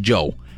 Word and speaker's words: Djo: De hecho, la D es completamente nuda y se pronuncia exactamente igual que su nombre, ¿no? Joe. Así, porque es D, J, O, Joe.